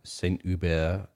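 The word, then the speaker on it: Saint-Hubert: But he grew up in a small town outside of Quebec, a place called Saint, well, this is how they pronounce it if you're French, Saint Hubert or Hubert is how it's spelled.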